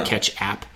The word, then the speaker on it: Catch App: Like it's spelled like ketchup, but yeah. Catch App.